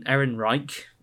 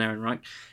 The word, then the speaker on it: Ehrenreich: Erin Reich. I believe that's how it's pronounced. Alden Ehrenreich his performance was not shit.